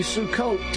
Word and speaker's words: Sukkot: Abe, happy Sukkot to you.